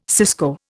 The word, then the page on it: cisco: Indian